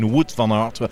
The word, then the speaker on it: van Aert: I keep saying Wout van Aert, when I know very well it's Wout van Aert.